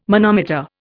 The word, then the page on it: manometer: Indian